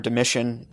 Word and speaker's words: Domitian: The next major persecution we see comes in the year 80 under the reign of the emperor Domitian. It's under Domitian's reign, or Domitian, some people will pronounce it, that he exiles John the Apostle to the island of Patmos, to kind of give you some type of historical context.